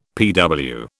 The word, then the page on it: PW: British English